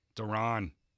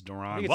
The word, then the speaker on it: Deron: We can't even remember how to pronounce his first name. I think it's Darren. – Deron. Pretty sure it's Deron.